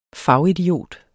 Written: fagidiot

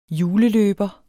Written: juleløber

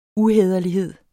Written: uhæderlighed

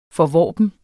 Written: forvorpen

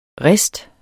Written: riste

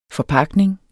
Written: FORPAKNINGER

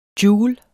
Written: joule

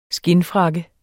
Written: skindfrakke

